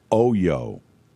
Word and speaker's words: Oyo: Oyo is how you pronounce it. – Rhymes with yo-yo.